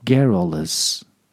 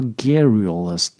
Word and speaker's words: garrulous: Little sense 啊，言多意少，有有那么一个形容词专门说这样的人，言多意少的人叫 garrulous，叫 garrulous。当然，有的字字典上也会说是呃。中间有一个 u 啊，发还是发 u 的音，在英式发音里面，它发 a 这个音；在美式发音里面，有的时候会发成 u 的音，就是 garrulous，garrulous。但是我觉得发 garrulous 太拗口了，所以我经常还是倾向于发 a 这个音，叫 garrulous。